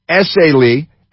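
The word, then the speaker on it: Essayli: Attorney, rather, for the Central District of California, Bill Essay, I know how to pronounce it. It's Essay Lee, said the FBI has offered a $50,000 award.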